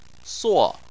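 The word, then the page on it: suo: tone 4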